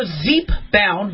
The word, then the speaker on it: Zepbound: Zepbound, or Zepbound, but I think it's pronounced Zepbound, is a new weight loss drug from drug maker Eli Lilly.